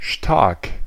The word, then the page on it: stark: [ʃtaːɐ̯k]; /ʃtaʁk/; /ʃtaɐ̯k/; /ʃtaːk/